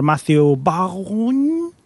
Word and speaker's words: Matthew Vaughn: Y han encargado al señor Matthew Vaughn. ¿Cómo se dice? ya lo has dicho bien.